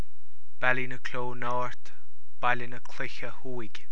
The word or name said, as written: Baile na Cloiche Thuaidh